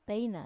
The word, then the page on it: teina: Wamesa